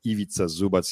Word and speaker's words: Ivica Zubac: America will never learn his name to pronounce his name because he's a role player, but it's Ivica Zubac. I really like that guy. It's really hard to pronounce because it doesn't make any sense, you know, in Anglo-Saxon way of reading the words. Ivica Zubac, he's a super nice guy, super smart guy, and he's a huge Jokic fan as well from way, way before.